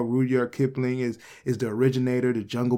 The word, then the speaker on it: Rudyard Kipling: Rudyard Kipling.